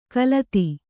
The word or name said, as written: ಖಲತಿ